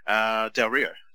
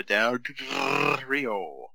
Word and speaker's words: Del Rio: Del Rio. So Del Rio. – Okay. Alberto Del Rio. Wait. Alberto Del Rio.